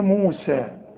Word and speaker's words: موسى: طب ما هي موسى فيها يه، واليه بتتحول إلى ألف في النطق. موسى، انا لغيت اليه لما وصلت قلت موسى الكتاب، الحكم ده هتاخده فيما بعد، لكن اذا وصلت الغي الياء مؤقتا. موسى الكتاب، وكأن الياء غير موجودة.